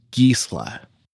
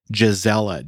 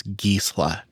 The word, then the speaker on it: Gisela: Now, there is a newer series of cherry rootstocks called Gisla. They were developed in Germany and they look like, if you were to spell them out, Gisela, G I S E L A, but the pronunciation is Gisla.